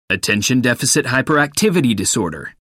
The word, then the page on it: attention deficit hyperactivity disorder: American English